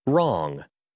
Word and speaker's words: wrong: 이 W 묵음 이제 확실히 기억할 수 있겠죠? 우리 처음에 들었던 영어 원어민의 음성 그러면 다시 한번 들어보고 또 한번 연습해 볼까요? Let's do it one last time. – Wrong.